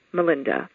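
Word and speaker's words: Malinda: So when I was 6 years old, I chose a new first name, Melinda. The most distinctive thing about my first name is that it's spelled with an A. That's M-A-L-I-N-D-A.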